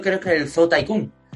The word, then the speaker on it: Tycoon: Cuando dices Tycoon, es lo que se escribe Ticon. – Ahí está, Ticon, ah, vale, vale. Tycoon, sí.